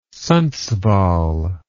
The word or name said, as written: Sundsvall